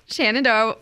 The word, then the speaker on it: Shenandoah: So Shenandoah, did I say it right that time? – Yep.